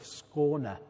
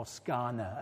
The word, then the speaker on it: Skåne: Skåne, as the Swedes say, or Skåne, as we say, we Brits say.